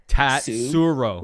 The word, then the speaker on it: Tatsuro: How do I say this? Tatsuro. – Pretty easy. – My mouth Say it phonetically. Say it phonetically. Tatsuro.